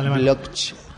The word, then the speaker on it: Bloch: No, que me gusta mucho cómo pronuncia Robert Bloch, que ya escribía en internet. – En alemán. Bloch. A mí me pasa otra cosa ya, antes.